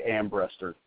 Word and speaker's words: Ambrester: Oh, it's everybody says it differently. I've gotten used to it. I say Ambrester.